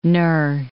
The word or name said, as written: knur